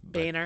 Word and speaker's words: Boehner: Is that how we pronounce his name? Boehner. – If you'd like to be amusing, yes. – How is it actually pronounced? – I believe it's Boehner. – Boehner.